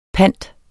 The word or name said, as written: PANTERNE